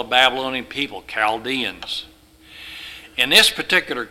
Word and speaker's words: Chaldeans: It's all pronounced Chaldeans. It's not Chal. The H is silent when you pronounce the word.